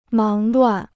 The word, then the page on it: 忙乱: máng luàn